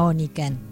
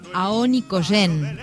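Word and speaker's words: Aonikenk: A primera vista digo, de la Oniken, no es de la Oniken, ¿no? Lo decíamos con Sandra recién. Una tiene tan grabada la canción A Oniken, Chaltén, pero no. Es Rosa del Aonicoyen, así es como se pronuncia correctamente.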